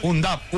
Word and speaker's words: Undav: Se escribe sin H. Un Duff. – Adelantado. Muy claro. Igual muy bueno. – Es decir, como un AT, pero no. – No. – UNDAV.